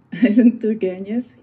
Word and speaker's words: Ivan Turgenev: And speaking of winter, I have been practicing with books about how to pronounce the name Ivan Turgenev. Is that correct? – Yes, very well, yes. Ivan Turgenev, yes.